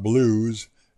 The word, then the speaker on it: Blooze: The album's called Got Blues, and that's spelled B-L-O-O-Z-E for blues.